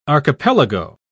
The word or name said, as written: archipelago